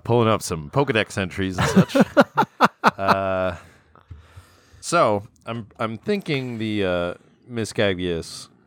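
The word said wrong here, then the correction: Mismagius: How do you say Miss Magius?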